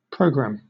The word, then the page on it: programme: British English